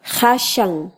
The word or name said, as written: jashan